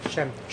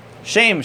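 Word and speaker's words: Shem (שם): Shem. – Shem.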